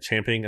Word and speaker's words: champing: really chomping at the bit to pay or I said, champing at the bit is really the correct way to say it, I believe, to pay running backs.